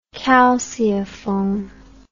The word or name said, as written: calceiform